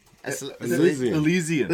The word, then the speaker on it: Elysian: How do you say this? Esliasian? – Elysian. – Elysian.